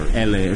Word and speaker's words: ELLE: Vogue or LA or something, one of these, you know. How do you say that? ELLE? – L. – L. Okay, L.